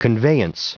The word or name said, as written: conveyance